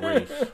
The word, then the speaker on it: Ralph: Well, I was going to give it a 5 until I found out the lead actor's name is pronounced Rafe.